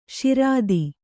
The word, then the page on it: ಶ್ರೋತ: Kannada